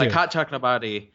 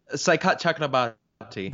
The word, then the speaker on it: Saikat Chakrabarty: Please so Psychotic Chakrabarty, This is AOC's chief.